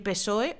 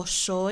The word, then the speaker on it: PSOE: El PP y el PSOE, o PSOE, e, como también se pronuncia, son los dos partidos principales en España.